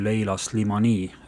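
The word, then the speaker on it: Leïla Slimani: mille pealkiri on Hälli laul autori nimi on Leila Slimani, hääldasin ma õigesti? just, väga õigesti hääldasid.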